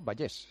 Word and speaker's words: Vallès: Claro, porque tú estabas en San Fruitós de Vallès, se dice así, ¿no? Vallès, se dice.